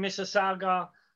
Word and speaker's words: Mississauga: he is also now the owner and president of Battle Arts Academy in Mississauga, Ontario.